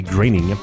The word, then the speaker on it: Groening: Ça a été ajouté au deuxième épisode, Bart the Genius, parce que le créateur, Matt Groening, oui, il faut bien prononcer Groening, pensait qu'une séquence d'ouverture un petit peu plus longue allait vouloir dire moins d'animation à créer pour l'épisode en tant que tel.